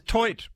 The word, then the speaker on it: toit: So does TOIT mean roof? – It's pronounced toi.